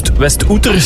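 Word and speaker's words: Westouter: Birgit uit West-Oeter, spreek je dat zo uit? – Ja. – Ja, ik twijfel altijd of het West-Outer is, maar het is in West-Vlaanderen zeker, hè? – Ja, dat klopt.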